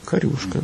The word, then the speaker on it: корюшка: Корюшка ударение было, да? – Корюшка, да. – В Ленинграде говорили корюшка.